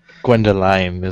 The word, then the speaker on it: Gwendoline: Well, I wasn't sure if I pronounced the E in it. Maybe this was some kind of variation of Gwendolyn. – Gwendoline? Gwendoline? I don't know. – Gwendoline is what I say.